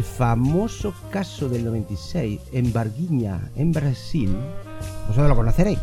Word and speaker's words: Varginha: Claro, es Varginha, pero se escribe Varginha con H intercalada. – Como vagina.